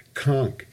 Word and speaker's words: conch: Conch has a CH at the end, which you don't pronounce, and it's a K sound.